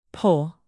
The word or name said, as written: poll